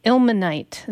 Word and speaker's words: Ilmenite: Ilmenite.